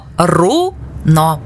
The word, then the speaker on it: руно: Правильно руно, наголос на у.